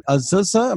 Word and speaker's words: Azusa: And Dr. Wilkins, he is a professor of philosophy and ethics at Azusa.